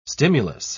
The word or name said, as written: stimulus